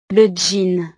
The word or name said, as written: le jean